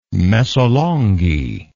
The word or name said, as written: mesolonghi